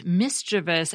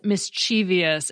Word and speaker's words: mischievous: And second, in a much larger infraction, I mispronounced mischievous as mischievous, and I am so sorry.